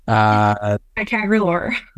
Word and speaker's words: Ticagrelor: Ticagrelor.